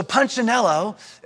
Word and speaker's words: Punchinello: And there's this one particular Wemek called, let me make sure I say it right, Punchinello. Punchinello. See, I see even say it wrong. My son corrects me all the time. He's like, Daddy, it's Punchinello.